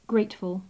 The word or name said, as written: grateful